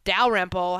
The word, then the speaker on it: Dalrymple: Take a look at Don Van Natta's story and what he wrote about the Cowboys issued Dalrymple. I think I'm saying that right. – That's correct.